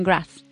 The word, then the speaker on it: Graz: in Graz? – Yeah.